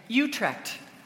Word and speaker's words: Utrecht: Utrecht.